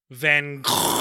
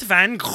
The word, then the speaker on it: van Gogh: Today's one comes to us from Michael Prefontaine, who says, how do you pronounce Vincent van Gogh? – This is how I pronounce it. Vincent van Gogh. – How to pronounce it correctly? – I just did. – This is a Dutch. – Oh, sorry. Vincent van Gogh. That was my Dutch accent.